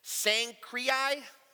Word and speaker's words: Cenchreae: At Cenchreae is how it's pronounced.